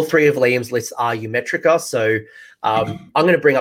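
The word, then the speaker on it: Ymetrica: So yeah Ymeritra. Ymeritra. I'm going to make sure that I say it correctly,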